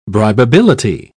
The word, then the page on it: bribability: British English